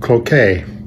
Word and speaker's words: Cloquet: Cloquet is pronounced Cloquet.